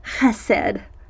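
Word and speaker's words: chesed (חסד): And that's the Hebrew word hesed, which I'm going to actually try to pronounce. It should be pronounced chesed.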